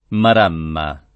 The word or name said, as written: maramma